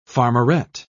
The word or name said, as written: farmerette